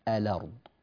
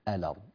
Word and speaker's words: الأرض: صارت هذه الألف لا وجود لها في الحقيقة. من اللام المفتوحة ننتقل مباشرة إلى الراء الساكنة، وصار النطق الأرض، الأرض، جميل، النقل واضح يا شيخ، احنا لا نحتاج إلى إعادة شرح له.